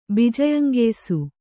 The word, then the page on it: ಬಿಜಯಂಗೆಯ್ಸು: Kannada